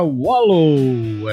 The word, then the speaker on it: Wallow: para vocês, primeira sequência, sem abrir com Georgia Wallow. É, se escreve Georgia, W.alô, que nem alô, tudo bem?